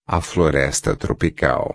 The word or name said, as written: floresta tropical